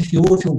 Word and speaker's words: Феофил: Лука пишет, он не был свидетелем всех событий, напоминаю, он не был среди 12, но по тщательному изучению всего пишу тебе, достопочтенный Феофил, говорят так правильно ударение, я привык к Феофил, вот, Феофил, значит,